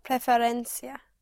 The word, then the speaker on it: preferencia: La preferencia. – Right. La preferencia. Yes. Yes, you push down on the penultimate syllable, on the syllable before last.